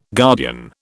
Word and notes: guardian: British English

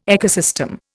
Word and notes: ecosystem: Indian

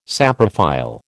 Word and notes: saprophile: American English

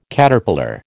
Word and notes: Caterpillar: American English

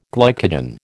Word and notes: glycogen: American English

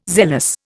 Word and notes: zealous: Indian

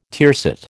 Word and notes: tiercet: American English

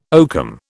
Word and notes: oakum: British English

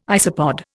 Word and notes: isopod: Indian

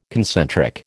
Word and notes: concentric: American English

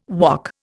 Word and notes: wok: Indian

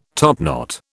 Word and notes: topknot: British English

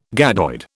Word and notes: gadoid: British English

